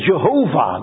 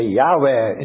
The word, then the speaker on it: Yahweh: We used to say in the older times, Jehovah, do you remember? But now the scholars have convinced everyone almost that it is a wrong pronunciation and that we must say Yahweh instead of Jehovah.